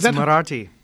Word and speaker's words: Marathi: His main area of research revolves around Marathi. – Marathi. – Marathi. Oh, okay.